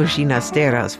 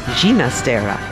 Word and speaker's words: Ginastera: Alberto Ginastera's father came to Argentina from Catalunya in Spain, where they pronounce the name Ginastera, not Ginastera.